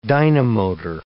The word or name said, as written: dynamotor